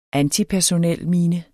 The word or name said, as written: antipersonelmine